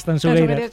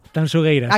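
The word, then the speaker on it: Tanxugueiras: Tanchugueiras. – Sí, esto me lo corrige Fermina, Agustín, mucho. Digo, mira que yo siempre pronuncio. A ver, otra vez, dímelo. – Tanchugueiras.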